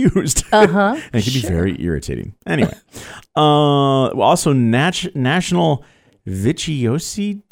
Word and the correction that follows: vichyssoise: Day. I'm not French, so I don't know how to pronounce that, but it's a thick soup made of boiled and pureed leeks. – Are you talking about vichyssoise? – Yes, of course. Vichyssoise. Maybe I should have had you say that one. – Okay. Well, I think that's how it's pronounced.